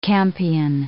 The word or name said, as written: campion